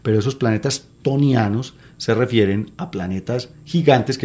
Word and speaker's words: ctonianos: A esos núcleos desnudos de planetas gigantes los han llamado tonianos. – ¿Tonianos? ¿Qué significa eso? – Yo en realidad el origen es una palabra griega que se escribe con C al principio. – C-T-O-N-I-A-N-O-S.